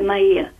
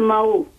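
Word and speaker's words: Mahut: I prezime izgovara Mau. Katastrofa, ja sam rekla.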